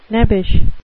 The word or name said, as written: nebbish